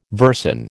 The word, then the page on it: versin: American English